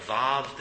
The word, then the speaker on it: Vav: And Vav starts in your Bibles, at least in my Bible, it says W-A-W. And some people will teach it differently how to say this letter. I was taught Vav that they can't, that the Hebrews had the V sound, not the W sound here.